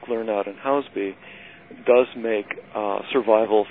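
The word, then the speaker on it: Lernout & Hauspie: and that's recently happened to Kurzweil Educational Systems, of course, with Lernout and Housby. Mm-hmm. I presume that's the correct pronunciation, because my speech synthesizer says it completely differently. – well, Lernout and Housby is correct.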